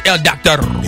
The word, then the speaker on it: doctor: You have to roll your R's. El doctor.